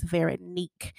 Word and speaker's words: Veronique: I had said her name wrong. It's Veronique.